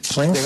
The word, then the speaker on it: Swansea: Bueno, es que me encontré un jugador hay un equipo que se llama Swansea, ¿se dice así? – Swansea.